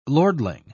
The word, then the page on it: lordling: British English